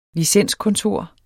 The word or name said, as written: licenskontor